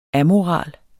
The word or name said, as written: amoral